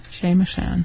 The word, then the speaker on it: Chez Machin: Chez Machin. – M-A-C-H-I-N. Chez Machin. – Think of the word machine without the E.